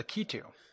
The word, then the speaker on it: Lakitu: Lakitu. – Okay, so it's in the wheelhouse of sounds with Lucky Toe. Lakitu.